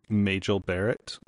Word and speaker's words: Majel Barrett: Majel Barrett.